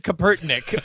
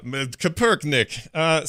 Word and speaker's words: Kaepernick: And I had heard of these players, specifically Colin Kaepernick kneeling. – Colin Kaepernick. – Kaepernick? – I think you said Kaepernick, like he was some sort of Russian scientist, but that's fine. – Yeah, Kaepernick.